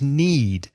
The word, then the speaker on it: knead: I need to, yeah, because the next word is need. Exactly the same pronunciation, but the spelling is K-N-E-A-D.